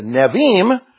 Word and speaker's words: Nevi'im: The Nevi'im is spelled N-E-V-I-I-M. Put an apostrophe between the two I's.